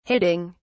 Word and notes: hitting: British English

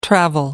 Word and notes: travel: American English

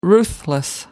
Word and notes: ruthless: American English

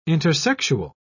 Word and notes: intersexual: British English

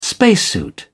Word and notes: spacesuit: British English

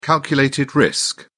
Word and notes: calculated risk: British English

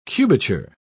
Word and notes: cubature: British English

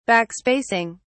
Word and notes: backspacing: British English

